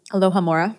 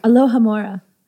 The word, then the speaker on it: Alohomora: She tried Alamora and several... Did I say that right? – Alohomora. – Alohomora. Alamora.